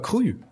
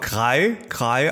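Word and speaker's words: Kry: Könnte man auch Krei aussprechen, aber das steht extra auf der Webseite schwedisch, warum man das, also das ist Krü ausgesprochen.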